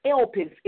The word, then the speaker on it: elpis: I say again, the Greek word for hope is elpis. It is spelled E-L-P-I-S.